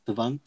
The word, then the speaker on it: Savant: Savant, yeah, that's how you pronounce it.